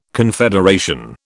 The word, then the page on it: confederation: British English